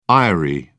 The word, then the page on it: eyrie: British English